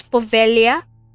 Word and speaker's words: Poveglia: I searched how to say this, and I've heard some people call it the Poveglia Island, and I heard Poveglia Island, so I searched how Italian people pronounce this word, and it's Poveglia, so I'm going to use Poveglia for its pronunciation.